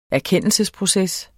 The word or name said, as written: erkendelsesproces